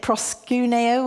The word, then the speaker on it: proskuneo: I think you pronounce it proskuneo or something. Yes. Oh, I've got a tick from the Greek teacher there.